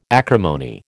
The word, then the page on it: acrimony: American English